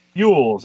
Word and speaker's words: Elwes: So, uh, Cary, how do, how do you, how do you say that guy's name? Elvis? Cary Elvis? Cary Yules. Elwes, there we go.